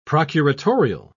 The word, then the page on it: procuratorial: British English